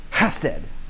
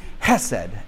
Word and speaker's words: Hesed: One of the biggest words in the Old Testament is, I'm going to try to say it correctly, Hesed. Hesed, basically H-E-S-E-D.